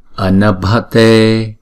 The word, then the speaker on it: Anabhate: Chữ B và chữ H ghép lại chúng ta đọc là bờ, bờ. Thấy không? Chữ bờ nhưng mà âm bật á, bờ. Anabhate. Thấy không? Anabh là một nhịp hết, còn chữ T là hai nhịp.